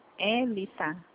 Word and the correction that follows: Elita: Elita não tem. Tem Elita aqui. Elita não. – Ah, sim, então eu pronunciei errado, desculpa.